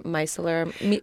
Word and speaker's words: micellar: Do you think use taking a cotton swiped in micellar. what also what is the correct French pronunciation? – Micellar.